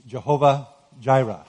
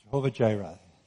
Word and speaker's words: Jehovah Jireh: The title of my message this morning is Jehovah Jireh. At least that's the way I pronounce it. It's not quite the way the Hebrews would pronounce it. But Jehovah Jireh, God who provides.